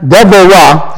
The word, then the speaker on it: Deborah: On that day, Deborah, Deborah, that's the way it's pronounced in the Hebrew, and Barak, son of Abinoam, saying, for leaders leading in Israel, for the people volunteering, bless Jehovah.